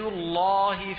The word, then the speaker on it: الله: فأنا أنطق باللام مغلظة بمعنى أني لا أنطقها مرققة، لأن اللام هي في الأصل حرف مستفل لكنها تغلظ في لفظ الجلالة إذا سبقت بفتح أو ضم. يد الله فوق أيديهم، إن الله معنا، إن الله، وهذه بدنا نشرح كيفيتها، هي كيفيتها بالتلقي.